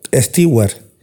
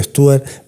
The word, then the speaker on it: Stewart: Bueno, salvo Rod Stewart, que parece que se pronuncia Stewart, pero por aquí abajo siempre hemos dicho Stewart, que rechazó y bastante que me alegro, actuar en el show.